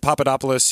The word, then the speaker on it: Papadopoulos: If you look at these two people, Gates and Manafort and Papadopoulos, he was the one who...